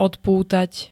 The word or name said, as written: odpútať